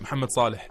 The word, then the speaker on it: Mohamed Salah: Hey, you pronounced it right, Mohamed Salah.